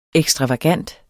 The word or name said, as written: ekstravagant